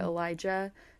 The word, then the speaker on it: Elisha: Elijah, I'm not sure where he moved from, and I said I think his name is Elijah because it's spelled E-L-I-S-A, and then I saw it spelled E-L-I-S-H-A, but then in the show they pronounced it Elijah, so The spelling makes me think he moved from somewhere.